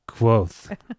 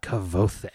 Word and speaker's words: Kvothe: Yeah, it's pronounced Quoth, not Kvothe.